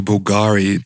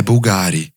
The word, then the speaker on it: Bulgari: e ela diz: é Bulgari. Então, essa mudança na pronúncia, que é uma pronúncia incorreta, seguindo de fato o padrão da búlgara italiana, mas mostra o quanto a marca, né, essa casa de joias, estava tão cosmopolita e tão já atualizada com o mundo que as pessoas estavam começando já a distorcer a pronúncia original.